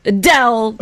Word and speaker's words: Adele: So it's, you don't really say the A. – Adele. It's just. – No, it's Adele. – I think that just depends on where you come from. – But that's how she says she pronounces it. So we have to learn it now.